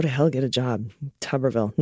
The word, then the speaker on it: Tuberville: The tub in Tuberville isn't pronounced tube, it's pronounced tub, like a bathtub.